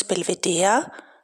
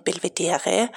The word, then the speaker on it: Belvedere: Mai 1955 in Wien im Schloss Belvedere, nicht wie oft falsch ausgesprochen Belvedere, im 3.